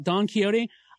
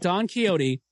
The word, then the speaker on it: Don Quixote: I listened to an In Our Time podcast about Don Quixote. I had no idea that across the pond, it is pronounced, in Britain, rather, it is pronounced Don Quixote.